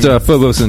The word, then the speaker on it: Phobos: It's pronounced Phobos and Deimos. Thank you.